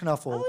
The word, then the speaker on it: Knuffle: It's a Dutch word that means hug or snuggle, and it's pronounced Knuffle.